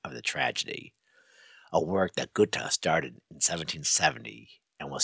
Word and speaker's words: Goethe: And then a young Johann Goethe, or as I pronounce it, Goethe, G-O-E-T-H-E, saw Faust puppet show, a little puppet show featuring Dr. Faust and the devil.